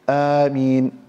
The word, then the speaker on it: آمين: And here it's recommended that you are to extend the pronunciation of Ameen, or تقرأها بالقصر, you recite it in a shortened way, Ameen.